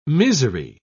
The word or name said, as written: misery